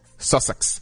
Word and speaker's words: Sussex: Sussex.